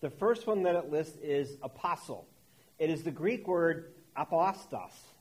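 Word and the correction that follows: Apostolos (ἀπόστολος): I didn't say that right. I'm going to blame that on breakfast. Apostolos.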